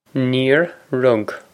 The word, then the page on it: Níor rug: Neer rug